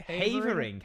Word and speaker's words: Havering: Havering. How'd you spell it? – H-A-V-E-R-I-N-G.